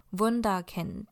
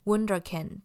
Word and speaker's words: Wunderkind: 第一个就是你可以照着德文的发音念，那就会是 Wunderkind。不过呢，你有可能也会听到有人念 Wunderkind，或者是 Wunderkind。